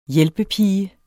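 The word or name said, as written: hjælpepige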